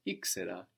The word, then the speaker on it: ήξερα: I knew is ήξερα. Ήξερα. – Ήξερα. – So again, another common verb, know, using ή to get an extra syllable, ήξερα.